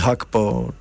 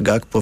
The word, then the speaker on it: Gakpo: Cody Gakpo, czy Gakpo, wybierz sobie wymowę, bo i tak jest poprawnie tak naprawdę, strzelił gola, po którym klęknął, schował głowę w dłonie.